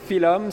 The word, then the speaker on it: Phylum: Philom, ça s'écrit P-H-Y-L-U-M.